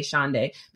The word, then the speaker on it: Shonday: Even the way that I get people to remember how to pronounce my name, I always say it, pronounce it like a day of the week, Saturday, Sunday, Shonday.